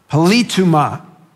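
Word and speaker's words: politeuma (πολίτευμα): Now, this term citizenship, politeuma, is how it's pronounced in the Greek.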